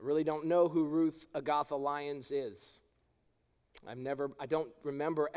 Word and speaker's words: Agatha: For all of those who are not Jamaican, you pronounce Agatha Agatha. And if you don't believe me, just ask Miss Riley. She'll tell you.